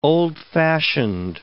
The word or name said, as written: old-fashioned